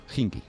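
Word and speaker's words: Khimki: Hinkie. – Pero es que algunos dicen Kinkie, ¿no? – Sí, porque se escribe KH, pero en ruso la KH es J.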